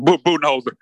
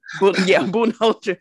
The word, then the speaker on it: Budenholzer: Budenholzer. – Budenholzer.